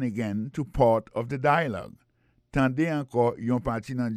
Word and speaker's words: dialogue: Tande yon parti in the dialogue, nan dialogue la. Alors mot dialogue ça c'est dialogue. Ça ne carrément manqué là, nous carrément manqué que l'accent tonique là tombé sous premier syllabe là.